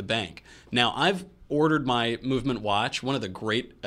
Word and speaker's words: MVMT: MVMT, but we pronounce it Movement, because look, we don't have time for all those vowels.